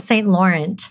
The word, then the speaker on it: St. Laurent: It's not quite, we don't pronounce it quite so French, but yeah, Corissa St. Laurent.